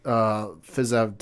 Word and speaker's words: Fiziev: Yeah, so this fight was originally supposed to be between Dos Anjos and Rafael Fiziev, I think is how you say it. Fiziev, yeah.